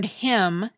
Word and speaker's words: hymn: The word hymn, as in a hymn that you sing in church, has a silent N at the end of it.